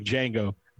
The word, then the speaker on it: Django: It's like Django. The D is silent.